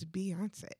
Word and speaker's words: Beyoncé: And not welcome to anybody who pronounces Beyonce as Bey. Because it doesn't make any sense. It's Beyonce.